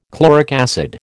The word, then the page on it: chloric acid: American English